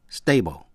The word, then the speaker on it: stable: Cũng nhận xét về cách phát âm tĩnh tự stable. Thì âm A đọc là A, stable, là vững. Còn danh tự stability, thì âm A đọc là A. Quý vị nghe lại, stable, stability.